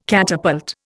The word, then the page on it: catapult: Indian